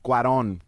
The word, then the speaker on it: Cuarón: Cuarón.